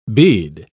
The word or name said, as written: bead